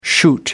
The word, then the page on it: shoot up: American English